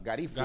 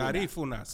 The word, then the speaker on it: Garífuna: Garífola. – Garífuna. – Garífunas. – El grupo Los Garífunos. Sí. – Garífono.